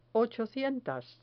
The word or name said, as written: ochocientas